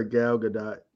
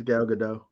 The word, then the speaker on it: Gal Gadot: I like how you call her Gal Gadot. I think it's Gal Gadot. – Whatever, it's Gal, okay? – I think you're right on the first part. You put the emphasis on the wrong syllable.